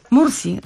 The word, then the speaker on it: Mursi: Ludzie z pasterskiego plemienia Murisz, dobrze wymawiam? – Mursi. – A jasne, Mursi.